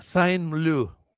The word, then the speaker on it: Saint-Meux: Saint-Meux se pronuncia, Saint-Meux. – Excusez-moi, parlez-vous. – Alexandra Saint-Meux. Así se pronuncia, Alexandra Saint-Meux. en francés, porque pues es francés el tema.